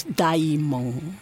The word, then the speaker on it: Daimon: Es, Daimon. Ah. Así nomás, como se, se escribe y se pronuncia.